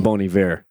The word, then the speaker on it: Bon Iver: We both endorse Justin Vernon, Bon Iver.